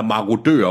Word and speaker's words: marodører: Ja, moradører, ja. – Wow. – Eller marodører, undskyld, jeg kan aldrig udtale det. Marodører. Det er, marodører.